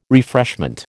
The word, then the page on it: refreshment: American English